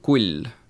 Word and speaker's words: Kuil: kes on perekonna nimega Kuil, kuigi seda peaks hääldama ikkagi Kull.